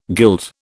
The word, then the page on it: guild: British English